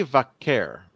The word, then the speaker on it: Vaquer: We see a promo from Stephanie Vaquer. Is that am I saying it right? Vaquer? – Yeah.